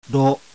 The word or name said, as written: RO